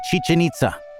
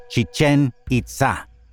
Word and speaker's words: Chichen Itza: Most people say Chichen Itza, with the accents on the first syllable of both words. The name is Chichen Itza, accented on the second syllable.